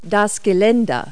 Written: railing

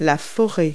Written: forest

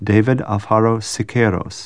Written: Siqueiro

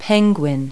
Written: penguin

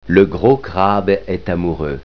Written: crabe

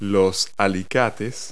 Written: pliers

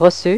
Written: recu

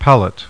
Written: PALLET

